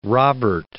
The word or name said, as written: Robert